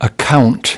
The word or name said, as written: account